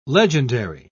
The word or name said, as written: legendary